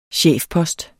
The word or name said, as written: CHEFPOSTEN